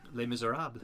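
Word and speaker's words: Les Misérables: How do you pronounce that shit? – Les Miserables.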